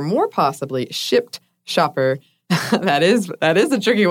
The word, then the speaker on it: Shipt: I work as an independent contractor for Shipt. Make sure you pronounce the P.